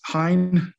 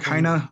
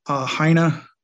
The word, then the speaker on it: Hein: The last name's Hein, like Heinrich Heine, the lyric poet from the 1800s.